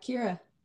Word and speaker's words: Keira: Yeah, Kira.